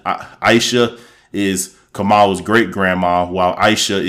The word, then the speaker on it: Aisha: I've been hearing people say Aisha, but someone on Twitter broke it down that it's not Aisha, it's Aisha. And when they said in the shows, okay, it is Aisha.